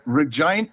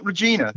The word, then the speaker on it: Regina: Re- Regina, uh, it's not Regina. – No, no, it's pronounced, the, the, the Canadians pronounce it Regina.